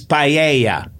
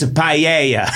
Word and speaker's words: paella: It's paella, okay? – I might have been doing it wrong my whole life. – Yes. – I might be that. – You've been living in Spain, and you're not saying it correctly. I'm from Massachusetts, and it's paella.